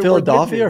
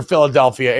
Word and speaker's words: Philadelphia: Philadelphia or Philadelphia? Is it Philadelphia? – No, you're saying Dolphia like Dolph Lundgren, and most people pronounce it Delphia like the Oracle of Delphi.